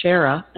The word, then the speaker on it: Shara: I mean, my name is Shara, and there's a running joke that rhymes with Sarah because it's always misspelled, and I get nothing, custom either.